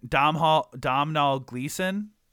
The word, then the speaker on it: Domhnall Gleeson: Domhnall Gleeson? – Yeah.